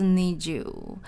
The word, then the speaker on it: 二十: Niju 的曲名也非常有趣，然后他们的歌，呃，真的是日本的大街小巷都在传唱，连嗯其他团的 idol 都会在其他节目上面唱这首歌，好像是因为有谐音梗，呃，日本的二十的发音就是 Niju，所以还蛮常常在综艺节目上面看到这个谐音梗。